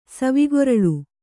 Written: ಸವಿಗೊರಳು

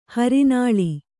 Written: ಹರಿನಾಳಿ

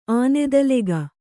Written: ಆನೆದಲೆಗ

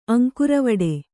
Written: ಅಂಕುರವಡೆ

